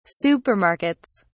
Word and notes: supermarkets: British English

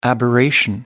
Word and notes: aberration: American English

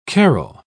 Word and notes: carrell: British English

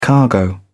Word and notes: cargo: British English

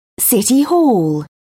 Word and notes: city hall: British English

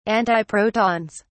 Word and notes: antiprotons: British English

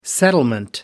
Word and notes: settlement: American English

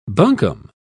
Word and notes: buncombe: British English

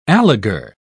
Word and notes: alegar: British English

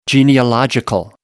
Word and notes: genealogical: American English

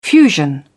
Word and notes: fusion: British English

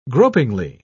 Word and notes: gropingly: British English

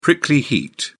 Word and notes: prickly heat: British English

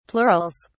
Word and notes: plurals: British English

